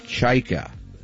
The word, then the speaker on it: Chajka: That is the correct pronunciation. Just think of chai lattes. Chajka. No? Yes, Chajka.